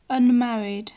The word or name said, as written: unmarried